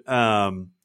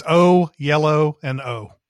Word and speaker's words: Oyelowo: It's David Oyelowo. Oyelowo. – Yeah, it's O, yellow, and O.